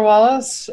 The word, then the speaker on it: Wallace: My name is Taylor Wallace, and you can find me on Twitter at T-A...